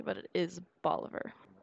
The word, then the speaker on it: Bolivar: Today is going to be the Bolivar Point Lighthouse, which that is how you pronounce it, because I looked online, and Texas has a multi-page set of pronunciations for cities and locations in the state. – So what, like, it's like Oliver, but with a B.